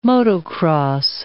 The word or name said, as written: motocross